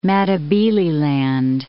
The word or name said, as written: Matabeleland